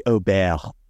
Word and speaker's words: Åberg: Well, in fairness to Neil, nobody knows how to say his last name. At one point, it was Ludvig Åberg. Now you get like the douchey. Now you get the douchey people like it's really Åberg.